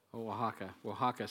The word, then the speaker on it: Oaxaca: Oaxaca State, which is where the big earthquake was in Mexico.